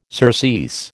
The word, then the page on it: surcease: American English